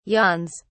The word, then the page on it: yawns: British English